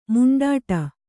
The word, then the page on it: ಮಿಂಡಾಡು: miṇḍāḍu